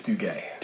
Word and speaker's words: Duguay: It's Duguay.